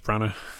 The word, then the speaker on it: Branagh: Yeah, I should say, Zizan, you don't pronounce the G in Kenneth Branagh. Just something to be aware of.